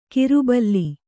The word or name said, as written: ಕಿರುಬಲ್ಲಿ